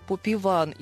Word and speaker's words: Поп Іван: Коректніше було б казати «Поп Іван» із наголосом на другому складі.